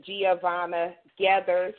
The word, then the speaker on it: Giovanna Geathers: We have a beautiful guest, Miss Giovanna Geathers, and I'm hoping I pronounced it correctly.